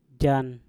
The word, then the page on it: jan: Mpur